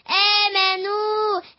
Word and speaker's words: آمنوا: امنوا ضمنوا امنوا واو مد طبيعي حركتين نو آمنوا آل سفر مستدير لا ينطق